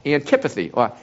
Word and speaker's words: antipathy: It was A-N-T-I-P-A-T-H-Y, and that is pronounced antipathy, can't even say it, antipathy.